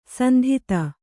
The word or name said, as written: ಸಂತತಿ